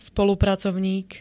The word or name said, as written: spolupracovník